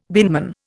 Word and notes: binman: Indian

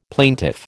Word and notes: plaintiff: American English

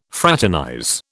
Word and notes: fraternize: British English

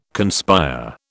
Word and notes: conspire: British English

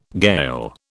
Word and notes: Gael: British English

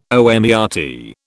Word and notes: omertà: British English

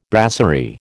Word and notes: brasserie: American English

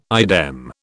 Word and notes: idem: British English

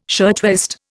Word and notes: shirtwaist: Indian

